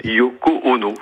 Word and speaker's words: Yoko Ono: Alors, je pense que c'est la veuve de John Lennon, Yoko Ono, si je prononce bien. – Vous prononcez très bien.